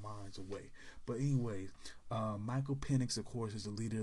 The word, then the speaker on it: Penix: I believe Penix, that's how you pronounce his name, Michael Penix.